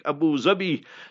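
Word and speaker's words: Abu Dhabi: So you, my brother, in Dubai, that is how you pronounce it in Arabic, Abu Zabi.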